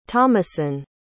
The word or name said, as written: thomasin